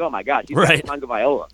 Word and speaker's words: Tagovailoa: I think Saban dilly-dallying around with Hurts or Tagovailoa, and am I saying his name right? Because for as many times as I've said we had to look it up, and I had to look up the pronunciation. Tagovailoa. – I think that... – Oh, Tagovailoa. Sorry, Chris. – Yeah, that's it.